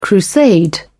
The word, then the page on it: crusade: British English